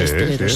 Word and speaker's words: esteleros: Dice Inma, en Canarias a los componedores de huesos se les llama esteleros. ¿Lo he dicho bien? Sí, sí, esteleros. Sí, estelero, sí.